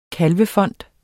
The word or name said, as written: kalvefond